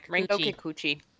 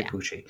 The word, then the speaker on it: Rinko Kikuchi: I think I'm saying her name wrong. Yeah, Rinko Kikuchi. – Yeah. Kikuchi.